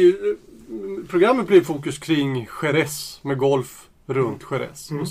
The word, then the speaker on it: Jerez: När man tittar på namnet sherry så är det ju egentligen någon slags språkförbristning bland engelsmännen som uppfattade, när man uttalar Jerez på spanska så blir det mer Jerez, och då blir det sherry på något sätt.